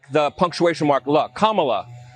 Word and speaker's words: Kamala: And Goodstein, who did a good job trying to tell Tucker tactfully, hey, her name is Kamala, and if you think about the punctuation comma and add a lot to it, you will get Kamala, and you can pronounce her name correctly.